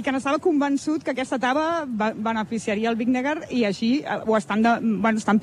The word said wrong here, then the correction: Vingegaard: Atenció com han pronunciat, sí, sí. Vingegaard, eh?